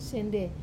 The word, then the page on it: sende: Wamesa